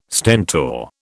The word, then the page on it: Stentor: British English